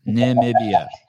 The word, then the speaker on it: Namibia: Namibia. Three syllables. Namibia.